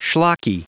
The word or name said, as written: schlocky